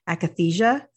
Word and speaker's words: akathisia: I want to make sure I say it right. Akathisia. Is that how I say it? Yeah. Mm-hmm.